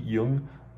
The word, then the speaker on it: Yeung: I think it's pronounced Y-E-U-N-G, Jung, Bolo Jung.